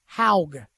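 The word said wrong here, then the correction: Hague: No, excuse me, Haig.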